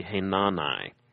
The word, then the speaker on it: Hanani: Technically, I think that when a name in Hebrew ends with an I, it's usually a long I. So usually this would be Hanani, which sounds a little awkward to our ears.